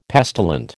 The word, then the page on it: pestilent: American English